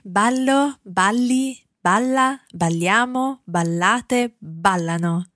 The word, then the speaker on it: ballano: Okay, Again, remembering that stress on the end of ballano, or indeed not on the end, but on the end of the verb conjugation, because we've got a different stress on ballano in comparison to all the other parts of that verb.